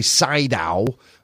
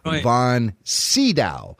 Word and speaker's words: von Sydow: Now, that is the correct pronunciation, Sydow. Uh-huh. A lot of us say Sydow, especially here in the States, but I believe the man pronounces his name von Sydow.